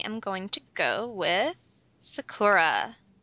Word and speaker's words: Sakura: Well, if you want to speak Japanese, you gotta roll your R, too, but... – Okay. I pick Sakura!